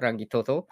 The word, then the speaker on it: Rangitoto: That's how you say it, Rangitoto.